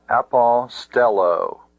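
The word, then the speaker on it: apostello (ἀποστέλλω): Notice that it has 1, 2, 3, 4 syllables. A-po-stel-lo. This is a verb. I send away. The ultima of this verb, lo, is a long ultima, a long syllable.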